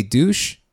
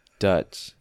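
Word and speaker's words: Deutch: It looks French. It makes me want to say douche. I don't think that's right. – No, Dutch.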